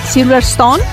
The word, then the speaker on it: Silverstone: Silverstone, ahora sí lo digo bien, y la propia hija del líder de Aerosmith, Liv Tyler.